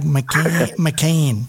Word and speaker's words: Maciain: That's because Maciain is actually Scottish Gaelic for Johnston. The Mac is son of, and the N is John in Scottish.